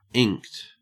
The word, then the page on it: inked: Australian English